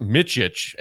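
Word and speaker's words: Micić: And it's this dude, I know that his last name is pronounced Micic, M-I-C-I-C, first name Vasilije.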